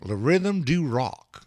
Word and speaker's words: Le Rythme du Rock: So so is your accent. – My accent? Oh, what? Le Rythme du Rock? Le Rythme du Rock. Well, I could say it like a, you know, Le Rythme du Rock. – There you go.